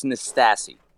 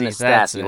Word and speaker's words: Nastasi: But it's Nastasi. – Nastasi. – It's Dan Nastasi. Like the old tennis player, Ely Nastasi. Oh. It's pronounced the same way, but... You know, some people say Nastasi or Nastasi or Nastasi or As long as you're calling me something, I really don't give a shit what you call me.